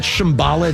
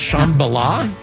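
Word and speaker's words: Shambala: It's not Shambhala, it's Shambhala? – That's how Three Dog Night said it.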